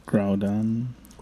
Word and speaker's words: Groudon: Groudon.